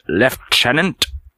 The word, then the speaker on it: lieutenant: In British English, the word is pronounced lieutenant, whereas in the United States, you'll hear lieutenant. You'll hear lieutenant instead of lieutenant. Okay, while both locations have kept the same spelling, you know, just to make it interesting, the U.S. pronunciation is heard increasingly often in other English-speaking countries.